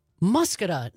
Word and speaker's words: Muscatine: Muscatine, not Muscatine.